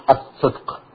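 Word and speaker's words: as-sidq (الصدق): Well, your pronunciation was close enough. It's as-sidq, yeah.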